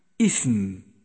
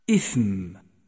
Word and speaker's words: إثم: ثانيا، جاء حرف الثاء مهموسا في كلمة إثم، فلا بد من جريان النفس معه عند النطق به ليكون واضحا، إثم إثم.